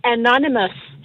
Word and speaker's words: Anonymous: Det hedder Anonymous, så det er NN og M til sidst så. A-N-O-N-Y-M-O-U-S, så er det Anonymous.